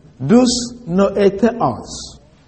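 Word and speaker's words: Dousnoetheos (δυσνόητος): The expression hard to understand comes from the Greek word dousnoetheos. Dousnoetheos is spelled D-U-S-N-O-E-T-O-S. Dousnoetheos.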